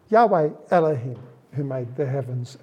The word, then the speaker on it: Yahweh: We don't know exactly how it's pronounced. Yahweh is probably the best pronunciation that we have. Sometimes people have called it Jehovah.